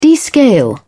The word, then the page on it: descale: British English